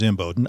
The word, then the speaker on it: Imboden: Now, that's spelled J-A-G-G-Y, and my last name is M. Bowden, I-M-B-O-D-E-N.